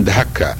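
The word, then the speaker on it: دكا: فبعضهم يقول انها إن انها آه، ينطق ان فيها شهقه آه، خفيفه فيقولون دهكه او يكتبونها دهكه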